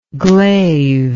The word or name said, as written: glaived